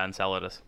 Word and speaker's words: Enceladus: Apparently, one of the other things Cassini taught us is that Saturn's moon Enceladus, I think I'm saying that right. – It's probably a Greek name, so yeah, Enceladus.